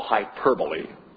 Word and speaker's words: hyperbole: And the other thing I'll say about this, remember that when Jesus preaches, he employs commonly a literary device we call hyperbole.